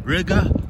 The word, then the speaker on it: Riga: So as you guys know, I'm out here in Riga, which rhymes with nigga.